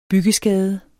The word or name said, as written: byggeskade